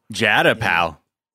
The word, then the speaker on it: Jada: I didn't know it was Jada. – Jada, pal. – Jada, pal. That's how we do it.